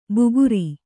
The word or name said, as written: ಬುಗುರಿ